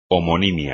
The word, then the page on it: homonimia: espagnol